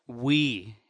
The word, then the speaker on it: Wii: Is it pronounced we or why? – It's pronounced we, as in you and I, as in everyone.